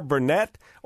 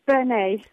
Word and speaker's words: Burnet: I'm happy to have, and she agreed to do the interview with me before we left town, Claire Burnett, or is it Bernay? I want to make sure I say it right, Claire. – Yes, it's Bernay, because it's because I'm married to a Frenchman. – Ah. – So we don't pronounce the T.